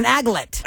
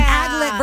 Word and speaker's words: aglet: It's an aglet. – Yeah. – An aglet versus an applet. An aglet.